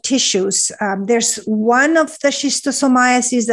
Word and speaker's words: schistosomiasis: You're also interested in, it's called snail fever, and I will potentially butcher this word, schistosomiasis.